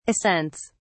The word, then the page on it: ascents: British English